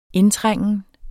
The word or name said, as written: indtrængen